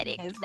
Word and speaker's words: Derek: o amigo Terek. Eu acho que é isso que se pronuncia, né? Aquelas é que é brasileira. Eu é acho Derek, que é Derek. – Derek. É isso.